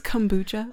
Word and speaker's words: kombucha: It's pronounced kombucha. – Oh, whatever. – Not kombucha. Ugh.